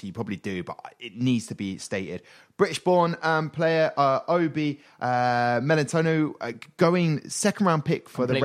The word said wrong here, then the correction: Melifonwe: I completely said his name wrong. – Melitono? – No, it's like Obi Melifonwe. It's like with an F. There's no T.